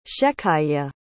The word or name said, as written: shecayah